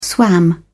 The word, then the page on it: swam: British English